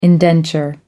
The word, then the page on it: indenture: American English